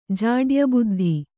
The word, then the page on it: ಜಾಡ್ಯಬುದ್ಧಿ: Kannada